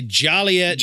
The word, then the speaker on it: Joliet: You gotta say Joliet Jake Blues.